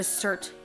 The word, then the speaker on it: SIRT: The program is called the CIRT Food Diet, and that is spelled S-I-R-T.